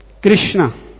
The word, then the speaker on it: Krishna: Lord, so whose complexion is Akrishna Varna, that means Pita Varna, and who always chant Krishna, 2 syllable, Krishna. In Sanskrit, 2 syllable, not in English.